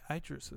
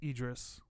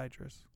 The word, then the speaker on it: Idris: Um, I think Idris is, or evidently it's Idris, not oh. Idris.